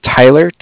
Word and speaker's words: Tyler: My name is Tyler, T-Y-L-E-R. – Mm-hmm. – And like I said, I'm calling with Cardiff. It's spelled C-A-R-D-I-F-F, as in Frank.